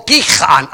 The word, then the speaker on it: Gihon: And the stream is, we would pronounce it Gihon. G-I-H-O-N. That's how we would think it would be pronounced.